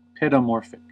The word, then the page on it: paedomorphic: American English